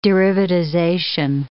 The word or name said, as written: derivatization